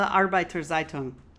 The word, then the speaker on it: Arbeiter Zeitung: It's called the Arbeiter Zeitung.